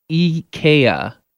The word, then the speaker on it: IKEA: The Swedish multinational conglomerate has come out to set the record straight on how to pronounce IKEA, because people weren't only pronouncing IKEA. Some were pronouncing it IKEA or IKEA or IKEA, but it is in fact IKEA. – IKEA. – If you say it in its natural born Swedish language, IKEA is how they pronounce it.